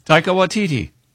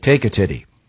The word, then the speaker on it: Taika Waititi: Pronounce it again, Taika? – Taika Waititi. – Okay. I thought it was like take a titty.